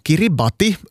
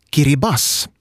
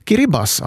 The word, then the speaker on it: Kiribati: På gilbertesiska, som det heter, det lokala språket, uttalas Gilberts som Kiribas. Där TI i slutet av då, Kiribati, då man läser det sådär ordagrant, uttalas som S. Kiribas alltså.